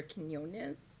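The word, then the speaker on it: Quiñones: Lester Quinones. Probably not pronouncing that completely correctly. – No, you got it.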